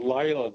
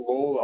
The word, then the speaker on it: Layla: Our best-selling, and I think it's pronounced Layla, the song, not Lawler.